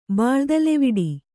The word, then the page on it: ಬಾಳುದಲೆವಿಡಿ: Kannada